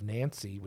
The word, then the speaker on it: Nancy: Yes, and there was a battle in 1477 called the Battle of Nancy, which is in France. That I pronounced right.